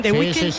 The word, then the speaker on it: The Weeknd: Miguel Ángel, lo he dicho bien, The Weeknd.